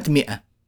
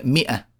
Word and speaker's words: مئة: مثلا كلمة مئة مكتوب ميم ألف ونبرة عليها همزة طبعا وإيه وتاء مربوطة المنطوق المنطوق الآن وإحنا بننطق أه، مئة هل نطقنا هذه الألف اللي بعد الميم؟ ها يا جماعة لا لم ننطقها طبعا لم ننطقها أبدا